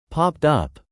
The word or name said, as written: popped up